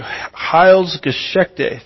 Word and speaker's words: Heilsgeschichte: How do you say that? Heilgeschichte? Is that how you say that? What is it? Thank you. Heilsgeschichte. Thank you so much.